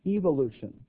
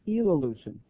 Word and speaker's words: evolution: I'm going to use the British pronunciation of the word. They say evolution, okay?